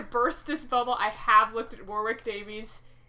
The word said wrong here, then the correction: Davis: Davis. You can't even get his name right.